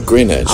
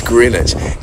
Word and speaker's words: Greenwich: No, Greenwich. – Oh. Yes. I know. – It's Greenwich. And isn't Greenwich where the Wombles come from?